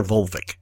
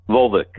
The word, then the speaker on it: Volvik: Is it Volvik or Volvik? How do you pronounce that correctly? – Volvik. – Volvik. – Volvik. – See, I was right the first time.